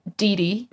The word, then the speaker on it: Didi: So my artist name now is Dee Dee, which is spelled D-I-D-I,